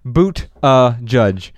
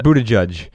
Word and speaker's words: Buttigieg: however you pronounce his name. Yeah, it's like boot uh judge. Yeah. Boot a judge.